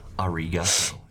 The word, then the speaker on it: arigato: Arigato. You gotta pronounce it right.